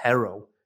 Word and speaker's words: Herro: So, and just a, a side note as well on on Tyler Herro.